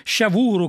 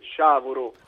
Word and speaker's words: Sciavuro: Volevo chiederle, ma come mai avete scelto un nome così sciavuro, così difficile da pronunciare? Cosa significa? – Intanto scusami se ti correggo, si dice sciavuro. – Ah, sciavuro, bene.